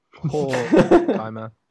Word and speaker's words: Horkheimer: Horkheimer. You need to put the emphasis on the first syllable.